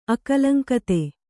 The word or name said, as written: ಅಕಲಂಕತೆ